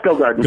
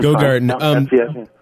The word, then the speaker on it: Gogarten: How do you pronounce that name? I always get That's Gogarten. – Gogarten.